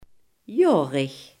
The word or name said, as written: jarig